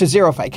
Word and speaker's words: xerophyte: Again, it's a xerophyte. Can you explain xerophyte to our listeners, please? – A xerophyte's a stupid word because it begins with X and it actually is pronounced with a Z.